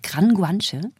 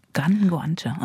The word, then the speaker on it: Gran Guanche: Ich habe gesehen, bei der Tour, du musst noch mal sagen, wie ich sie richtig ausspreche, Guanche oder wie, wie, wie sagt man? Man sagt äh, Gran Guanche. Gran Guanche, okay.